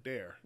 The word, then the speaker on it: Dare: No, not Dar. Dare. – Dare. – Every time you guys write it, it looks like Dar. – I know. And I'm like, but I want it to rhyme with bear.